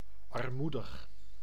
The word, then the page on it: armoedig: /ɑrˈmu.dəx/